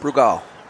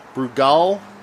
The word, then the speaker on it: Brugal: Brugal. – Brugal.